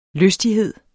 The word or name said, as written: lystighed